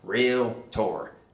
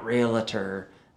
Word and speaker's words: realtor: The first thing they said was, it's two syllables, realtor, not realtor, not realtor.